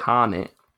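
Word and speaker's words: H'aanit: Harnett? I can't remember how you say it. – Harnett. – Yes!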